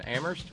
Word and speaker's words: Amherst: Thank you very much to Terry in Amherst, where only the H is silent.